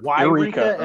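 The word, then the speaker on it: Yreka: There's Wyreka and there's Eureka. Yeah. – Okay, so you do pronounce that Y on the 101. – Yeah, yeah. – Yeah, yeah, yeah. – So okay, so you pronounce it Wyreka though.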